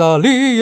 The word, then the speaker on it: Ali: Well, I saw the bottle, and I assume it's pronounced Ali, but it A-L-I. – Think of Prince Ali, yes, it is he, Ali.